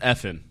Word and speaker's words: Effen: F&N. – It's pronounced F-N.